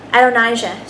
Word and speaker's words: Adonijah: So for David's son, and does anybody know how to pronounce his name well so I don't botch it? Adonijah? Is that how you say it? Adonijah? I bet. Because Adonai? Yeah. Adonijah.